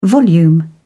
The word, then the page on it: volume: British English